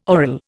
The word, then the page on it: aural: Indian